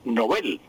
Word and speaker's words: Nobel: Decíamos Nobel, y resulta que no es Nobel, sino Nobel. Así se pronuncia el sueco y así pasa al español, porque repito, los nombres extranjeros pasan al español con la misma pronunciación que tienen en su idioma original.